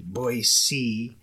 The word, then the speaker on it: Boise: Boise. – Man, I've gotten torn up about that before. – Boise.